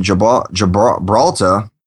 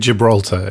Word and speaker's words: Gibraltar: And we got some feedback from Richard that says, regarding China's island airstrip, Britain has done a very similar thing in Gibraltar. Sorry about that. – Gibraltar, it's called. – Yeah, could not pronounce that for anything.